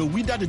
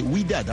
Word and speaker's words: Wydad: Oui, le Wydad de Casablanca, nous sommes au Maroc, chez notre... – J'ai toujours eu un problème pour prononcer ça, c'est Wydad, hein.